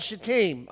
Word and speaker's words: Shittim: And Moses dies there where the triangle is drawn, and they are camped at a place called Shittim. Okay, I know what it looks like, but that's how you pronounce it.